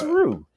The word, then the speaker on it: Ryu: Why do you say his name like that? What is Because that's how you say his name. It's Ryu. – Yes. – It is not Ryu. It's not Ryu. It's Ryu. – I feel like you've never actually watched any Street Fighter media. It is always Ryu. Every single time it is Ryu.